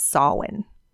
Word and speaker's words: Samhain: And I know It's spelt Samhain, like the Danzig band, if you are from the 90s. But it is actually pronounced in Gaelic as Samhain.